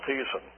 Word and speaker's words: Thiessen: There is a man by the name of Thiessen, a German scholar, so his name would be pronounced Thiessen,